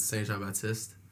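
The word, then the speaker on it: Saint-Jean-Baptiste: So, basically, I guess if I say it in French, it's Saint Jean Baptiste.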